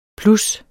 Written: plus

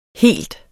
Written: helt

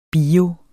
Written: BIOS

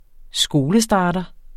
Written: skolestarter

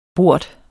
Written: bort

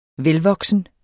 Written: VELVOKSENT

